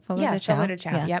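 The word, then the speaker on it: Fogo de Chão: Fogo de Chao.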